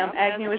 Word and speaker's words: Agnew: A-G-N-E-W. Right. – Yeah. – Right. – Yeah, that's definitely one. Like I said, it's said the way it's spelled, but you Everybody expects names to be, you know, spelled differently than they actually sound or something.